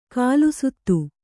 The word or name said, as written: ಖಾಲಸಾತಿ